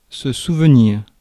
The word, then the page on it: memory: /ˈmɛməɹi/